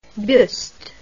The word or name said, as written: Buist